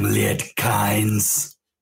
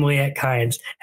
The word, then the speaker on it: Liet Kynes: He falls, pushes some stuff up, and he pronounces his name. – I am Liet Kynes. – I am Liet Kynes.